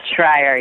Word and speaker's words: Schreier: And I do have your pronunciation of your last name correct, Schreier. – You got it, Schreier, yes.